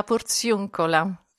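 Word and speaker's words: Porziuncola: J'ai encore mal prononcé, de la Portioncula. – Oui, la Portioncula.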